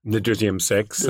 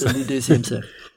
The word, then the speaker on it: Le deuxième sexe: How would you pronounce it in French? Your French is better than mine. – Le deuxième sexe. – Le deuxième sexe.